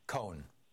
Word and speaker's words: Cohn: Then finally McCarthy and Ray, you said Ray Cohn or Ray Cohen? – Cohn. One syllable. – Cohen, okay, C-O-H-N.